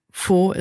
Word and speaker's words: Faux: Also, es heißt Faux ist falsch.